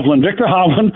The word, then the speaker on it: Hovland: Help me with the pronunciation. Victor Hovland. – Victor Hovland.